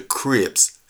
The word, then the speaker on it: Krebs: But they were all involved where he said the oxidation cascade is called the Krebs cycle. And that's spelled K-R-E-B-S. K-R-E-B-S, the Krebs cycle.